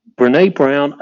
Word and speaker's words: Brene Brown: Brene Brown.